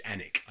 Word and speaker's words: Alnwick: I only say this because you can get direct to a train to Alnwick is Annick. I know that is pronounced Annick, so I may be that it's Anmouth, and Anmouth in the Pride of Anne.